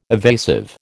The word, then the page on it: evasive: American English